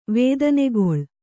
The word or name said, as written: ವೇದನೆಗೊಳ್